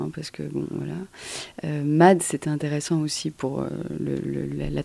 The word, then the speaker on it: MAD: Alors on dit MAD, mais ça vient, c'est l'anagramme de Mutual Assured Destruction.